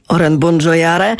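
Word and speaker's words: Obongjayar: Oren Bongiojare. Oren Bongiojare. Tak naprawdę pisze się go Obongjiajar, ale przez J i przez Y, ale on bardzo wszędzie tłumaczy, że czyta się to Oren Bongiojare.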